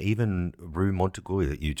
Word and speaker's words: Montorgueil: Montorgueil, and I love Rue Claire and Rue de Martyr, Rue de Rosier.